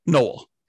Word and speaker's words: Noel: Cause I have to, I used to correct people on my first name, Noel, it's Noel.